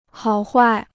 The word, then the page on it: 好坏: hǎo huài